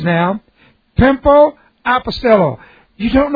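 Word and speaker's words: pimpo (πέμπω): The word to send here is the future indicative of the word pimpo. P-E-M. P long O, pimpo.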